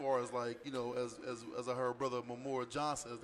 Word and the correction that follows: Umar Johnson: I think I said his name right. – Umar Johnson. Yes.